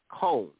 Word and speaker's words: Cone: All right, that's Cone, rhymes with Jones.